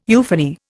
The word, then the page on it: euphony: Indian